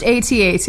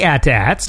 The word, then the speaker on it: AT-AT: It's pronounced AT-AT, Andy. – They say AT-ATs, too. – No, people say AT-AT. – Some people do, but it's... – No, that's incorrect. AT-AT. – I mean, I know Nerd that, like, alert. You would say A-T-S-T, you wouldn't say AT-AT, so I get that.